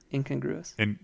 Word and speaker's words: incongruous: So there's something incongruitous? That's not right. – Incongruous? – Without the T, yeah. Incongruous.